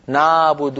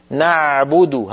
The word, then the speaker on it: نعبد: من جملة ذلك عين كلمة نعبد، لا تنطقوها بارك الله فيكم عالية نعبد هكذا نا، بل هي أعمق قليلا. العين الفصيحة أعمق من العين التي تعودنا في العاميات أن ننطقها. نعبد هكذا العين، نعبد أع نعبد، ولا نقول نابد نا، هذا صوت فموي ليس له مدلول، أقرب إلى الألف هو.